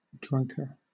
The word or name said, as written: drunker